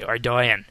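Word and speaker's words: Ardougne: Well, we both say it wrong, because that's what Jagex says it should be, Ardoyan.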